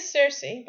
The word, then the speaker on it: Cersei: I was thinking that I don't think I've ever heard Cersei spoken aloud in English, and I was kind of somehow confused to hear people say it with a soft C.